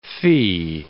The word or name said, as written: fee